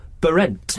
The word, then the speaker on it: Behrendt: Is it Behrendt? Is that how you pronounce his surname? Behrendt. – Greg Behrendt. Just say it in American accent. – He's got one of those names you have to have an American accent to pronounce, don't you? Because it's spelled B-E-H-R-E-N-D-T. Behrendt. – B-E-H.